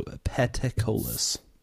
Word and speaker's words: Peticolas: It is spelled Peticolas. So Petacolis.